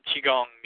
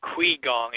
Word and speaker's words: qigong: It's either Qigong or Qigong. It's spelled Q-U-I. – Yeah, but it's a Chinese word, so that Q-I is a Qi.